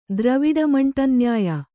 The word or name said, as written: ದ್ರವಿಡಮಂಟಂನ್ಯಾಯ